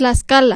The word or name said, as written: Tlaxcala